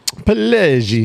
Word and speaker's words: Pileggi: Pileggi? How do you think? – Pileggi. – Pileggi. – Spot on. – And I put my fingers together when I said that up in the air. So that's the correct way to say it.